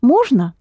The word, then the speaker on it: можно: Можно. – Можно, yes.